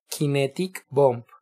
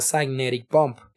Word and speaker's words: Kinetic Bomb: En inicios se dice como en español, eh, con una pronunciación literal, kinetic bomb, porque se pronuncia cynetic bomb.